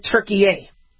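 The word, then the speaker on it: Türkiye: but Turkey A. So it's pronounced like you know, turnkey, yay! Turkey A.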